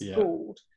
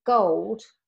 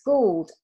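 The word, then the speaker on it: Goold: So she's G-O-O-L-D, but people don't always know how to pronounce that. So it's Gould. And so she played Gold by Spandau Ballet. And then she said, no, it's Gould after she played the song.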